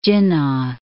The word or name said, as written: Jinnah